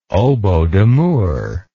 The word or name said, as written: oboe d'amour